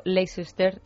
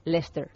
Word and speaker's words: Leicester: A uno, por ejemplo, nos afea la conducta porque hemos dicho Leicester cuando se dice Lester, porque él vive ahí.